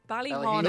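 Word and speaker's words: Ballyhaunis: I'm going to butcher the pronunciation of it, but it's Ballyhinnis. – Ballyhinnis. – No, that's good.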